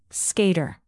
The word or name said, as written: skater